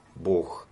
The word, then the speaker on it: Бог: Во-первых, почему Бог проговаривает, это слово слышно всегда. Б-О-О-Х. Для меня это просто вздох, это выдох, Бог. Слово произносится на выдохе.